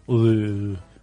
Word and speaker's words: Louvre: Louvre. – Is it? – Louvre. – Right. – Louvre. You have to say it like that.